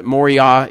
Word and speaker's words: Moriah: if you want to pronounce it Moriah, Moriah, that's fine, but Moriah is probably the correct pronunciation.